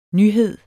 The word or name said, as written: nyhed